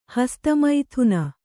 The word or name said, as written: ಹಸ್ತಮೈಥುನ